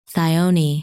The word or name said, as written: Thyone